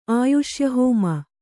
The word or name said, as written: ಆಯುಷ್ಯಹೋಮ